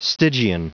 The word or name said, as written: stygian